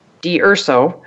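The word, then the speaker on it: D'Urso: So, and ladies and gentlemen, to get his vision map, just go to Tony DUrso. I said it right, I think, this time. – Yay. Well, you said it the Italian way.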